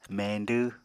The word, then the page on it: meñdw: Apatani